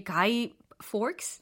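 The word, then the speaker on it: Guy Fawkes: 그 사람 이름이 가이 forks. – Mm-hmm, 예, yes. 이렇게 발음을 하는 것 같은데. – Yeah, that's right.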